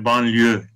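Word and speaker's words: banlieue: Evet, şimdi bu kelime çok ilginç bir kelime, banlio kelimesi. Türkçe'de telaffuzu aynen Fransızca'da olduğu gibi. Yani Fransızca'da bu kelime nasıl telaffuz ediliyorsa Türkiye'de de aynı şekilde telaffuz ediliyor.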